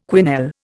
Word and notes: quenelle: Indian

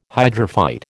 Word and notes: hydrophyte: American English

